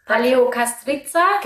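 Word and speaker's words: Paleokastritsa: Ja, also es war echt krass voll, weil es war, du hast es vorhin viel besser ausgesprochen. – Paleo Castriza? – Genau.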